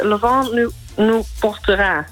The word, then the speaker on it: Le Vent nous portera: Maar het is Le Vent nous portera. – Ja, doe ik het voor. Ja, goed uitgesproken. Ik ga hem voor je draaien.